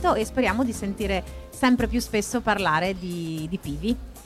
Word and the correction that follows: Pivi: Che sbaglio sempre l'accento, Pivi.